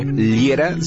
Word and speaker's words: Liera: Mi nombre, Fernando Liera, se escribe L-I-E-R-A.